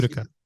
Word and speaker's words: Utica: Is that pronunciation Utica or Utica? How is it pronounced? – Utica.